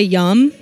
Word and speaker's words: miam: I mean, it's actually how the French people say yum. They spell it M I A M. And it's yum, yum, yum, yum, yum.